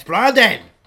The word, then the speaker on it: Brendan: No one in that family is allowed to pronounce Brendan Gleeson. He looks at you when you say Brendan. He goes, "It's Brandon."